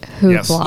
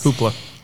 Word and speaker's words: Hoopla: And I want to kick it off with my personal favorite, Hoopla, right? Is that how you say it? Hoopla. – Yes. Hoopla.